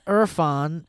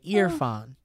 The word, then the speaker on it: Irfan: My name is Irfan, pronounced ear, like the body part, and fawn like a baby deer. Okay, ear fawn.